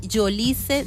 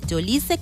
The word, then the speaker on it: Yolice: Mi Instagram, para quien desee seguirme y ver todas las cosas que eh, estamos haciendo, soy Yolice Zapata. Yolice con Y y C. Y-O-L-I-C-E.